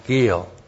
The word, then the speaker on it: gil: G-I, long I-L, gil.